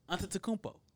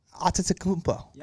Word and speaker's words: Antetokounmpo: Antetokounmpo. – Antetokounmpo. – Antetokounmpo.